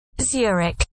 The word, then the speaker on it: Zurich: Or is it Zurich? Huh? – Zurich. Zurich. – Okay, so it's Zurich, not Zurich or Zurich. It's Zurich. Okay, thank you.